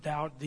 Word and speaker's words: doubt: Have you ever thought about that word doubt, D-O-U-B-T? Where do we get the B in doubt? There's no B in doubt. It's D-O-U-T. But we spell it D-O-U-B-T. The reason is it comes from the root word, double.